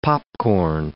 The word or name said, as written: popcorn